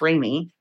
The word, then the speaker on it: Remi: It's R-E-M-I, pronounced Remy.